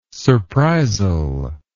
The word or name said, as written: surprisal